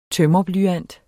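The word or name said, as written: tømrerblyant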